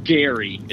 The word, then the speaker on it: Guerry: It's pronounced Gary, as if it was Gary. G-A-R-Y.